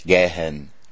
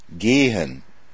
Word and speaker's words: gehen: Haus, H-A-O-S, Schule, S-Z-U-L-E, und gehen? Nicht gehen, gehen. Gehen? Das Polnische hat kein langes E.